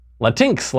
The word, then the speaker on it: Latinx: L-A-T-I-N-X. Latinx, Latinx. I've heard it pronounced both ways.